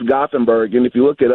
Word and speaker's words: Gothenburg: I think I might have mispronounced Gothenburg. Hey. – Hey, how we doing, Dan? – Great. – Yeah, it's Gothenburg, actually.